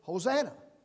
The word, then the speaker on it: Hosanna: And that word was now then pronounced the way we pronounce it, Hosanna. It's no longer Hoshi'ana, meaning save, please, but now it's the word, it's a word that says, sounds like Hosanna, and now the word means salvation is now on its way.